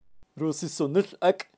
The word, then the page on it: rusisonil ak: Kaqchikel